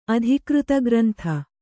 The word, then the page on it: ಅಧಿಕೃತಗ್ರಂಥ: Kannada